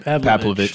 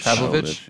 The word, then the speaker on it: Pavlovich: I think Plahovic, that's how you say it, right? – Pavlovich. – Pavlovich, yeah.